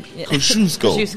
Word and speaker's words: Kosciuszko: That's what I called it out after I messed up and said Kazuki. – Because nobody can pronounce it. – No, nobody. Now you can. Kosciuszko. – Kosciuszko. – Kosciuszko Park is here.